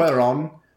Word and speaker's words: Cuarón: It's filmed entirely in black and white, and it's by Alfonso Cuarón. That's not how you say his name. – Cuaron, I think. – Cuaron.